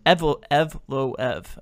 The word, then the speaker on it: Evloev: Is it, how do you say his last name, the second guy? – Evloev.